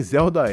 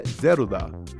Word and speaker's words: Zelda: Aliás, nem Zelda é. A pronúncia correta é Zeruda, já que é uma banda japonesa formada só por mulheres e que tocou de tudo na carreira.